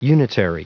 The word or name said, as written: unitary